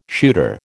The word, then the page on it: shooter: American English